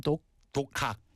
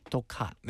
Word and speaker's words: 독학: Ah, how do you say self-study in Korean one more time? – 독학. – 독학. – 네, 독학.